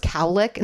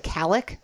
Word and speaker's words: cowlick: Well, first of all, did you know it was cowlick instead of calic? – No, I didn't. – Did you think it was calic? – I never put much thought into the pronunciation or spelling. – But you were shocked to find out it's cowlick, like a cow licks your hair.